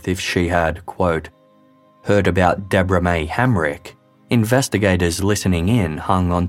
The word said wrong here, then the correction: Helmick: Realizing he had mispronounced Deborah May's surname, the caller corrected himself and said Helmick.